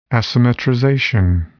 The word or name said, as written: asymmetrization